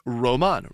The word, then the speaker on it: Romain: It's spelled Romaine, like the lettuce. – Like the lettuce, okay. – But it's pronounced Roman.